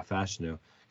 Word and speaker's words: Fashanu: I, I Olu or yeah, Fashanu Gotta make sure we say that right now because we keep saying wrong for so long.